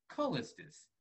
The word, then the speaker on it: Carllistus: So my name is pronounced Carllistus. However, to honor the person who was me before me, I pronounce my name with the silent R, Callistus.